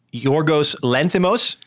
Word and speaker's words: Yorgos Lanthimos: um, it is the favorite, the Yorgos Lanthimos. Did I say that correctly? I'd say so, but then I also have never heard anybody say it.